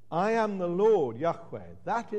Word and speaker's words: Yahweh: The name that in English is normally written as Jehovah, but was probably pronounced Yahweh.